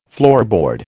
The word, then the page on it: floorboard: American English